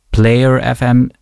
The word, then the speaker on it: plyr.fm: Die heißt Player FM. Du musst das aussprechen, aber es wird geschrieben PLYR.FM.